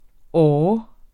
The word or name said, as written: året